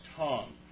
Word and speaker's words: tongue: So the host was a little embarrassed this time and said, well, actually, my friend, it's pronounced tongue. Whenever you have the U-E on the end of words, often it's silent, okay?